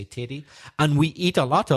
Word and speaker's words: tatie: T-A-T-I-E, tatty. – But pronounced teddy. – Okay, because tatty I've heard of as meaning potato. – Yes, in Scotland. – Right. – But in Northern Ireland, no, we'd say teddy.